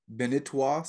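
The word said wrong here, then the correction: Balintawak: system. Is that right? – Balintawa.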